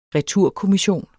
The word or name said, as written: returkommission